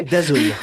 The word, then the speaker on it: DAZN: DAZN.